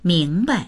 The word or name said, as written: ming2bai